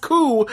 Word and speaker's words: coup: Rich, no, it's spelled coup, but it's pronounced cool.